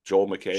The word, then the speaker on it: Joel McHale: Following the latest episode of, how do you say that dude's last name for Animal Control? It's just McHale? Joel McHale? – Sure.